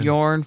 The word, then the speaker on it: Jorn: Jorn, fuck, you said it wrong. – Jorn. – No, like the guy had a shirt. You see the guy's shirt? – Jorn fucking Lande.